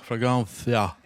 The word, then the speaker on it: Fragancia: Ska det inte uttalas så på spanska? Fragancia. Är det rätt?